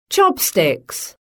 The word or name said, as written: chopsticks